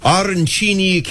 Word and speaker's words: arancini: And if you are looking for something that is a little more pasta-based, tell me about the arancini casserole. Did I say it right? – You did.